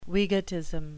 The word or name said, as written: wegotism